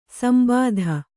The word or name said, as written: ಸಂಬಾಧ